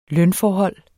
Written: lønforhold

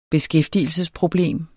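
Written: beskæftigelsesproblem

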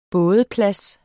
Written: bådeplads

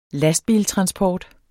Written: lastbiltransport

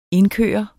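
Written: INDKØRT

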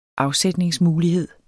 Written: afsætningsmulighed

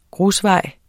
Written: grusvej

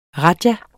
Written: rajah

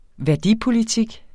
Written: værdipolitik

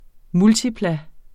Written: multiplum

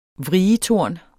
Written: vrietorn

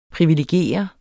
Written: privilegere

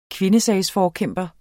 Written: kvindesagsforkæmper

